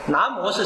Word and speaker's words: 南无: 字面上来说，南无是什么意思？这个魔不要念成无啊，这个现代人一看南无阿弥陀佛，甚至一些导游还自己发挥一下，什么叫南无阿弥陀佛呢？